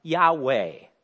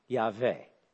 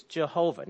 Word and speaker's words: Yahweh: Now, frequently it's pronounced Yahweh. But what's interesting there, reading from right to left, the third consonant really is almost always pronounced as a V sound, Yahweh.